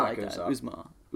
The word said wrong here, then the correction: Uzomah: Uz, Uzomah.